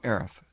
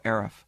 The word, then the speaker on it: Arif: I go, Arif. They go, Eric? I said, no, Arif. Aris? No, Arif.